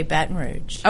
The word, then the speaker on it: Baton Rouge: And do you say Baton Rouge or... How do you say that word? – I say Baton Rouge.